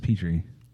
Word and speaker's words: Petrie: It is how it's spelled, but yeah, that's Petrie. – Pet Rye. – I thought it was Y, not an E-I-E.